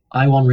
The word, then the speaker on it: Iwan: It's Welsh. This is good. Ivan. No. Iwan Rion.